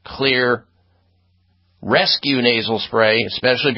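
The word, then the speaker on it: Xlear: Clear Max nasal spray and Clear, spelled with an X, X-L-E-A-R, X-L-E-A-R, Clear Rescue nasal spray.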